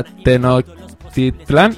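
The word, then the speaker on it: Tenochtitlan: Es mexicano quien lo comenta, representando a Tenochtitlán. – Tenochtitlán, ¿te lo he dicho bien? Bien, bien. Pues nada, ahí va un saludito también para los mexicanos que nos estén escuchando en la radio.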